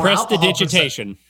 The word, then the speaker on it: prestidigitation: Was expelled from magic college and chased off the premises for accidentally blowing up the East Wing when using my prestidigation attempt to add more prestidigitation. – Alcohol. Prestidigitation, right? – That's close enough.